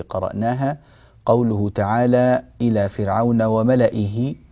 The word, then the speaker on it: وملئه: نلاحظ أن كلمة ملئه كتبت بميم ثم لام ثم همزة وبعد الهمزة ياء ثم هاء، وملئيه، ولكن هذه الياء زائدة في الرسم لا تنطق في القراءة، وإنما نقرأها وملئه، فلا يوجد نهائيا أي نطق لهذه الياء لا في حال الوصل ولا في حال الوقف، حتى لو وقفنا نقول وملئه ولا نقول وملئيه، ما فيش هذه الياء غير موجودة نهائيا إلا في الكتابة، وهي زائدة في رسم المصحف.